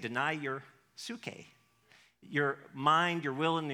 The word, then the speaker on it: psyche: It sounds like psyche, but it's spelled P-S-Y-C-H-E. It's psych, It's where we get the word psychology, and that has to do with our soul.